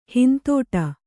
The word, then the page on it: ಹಿಂದಡಿ: hindaḍi